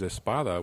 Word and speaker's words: Espada: But I refer to my last name, which is Espada, which, you know, E-S-P-A-D-A.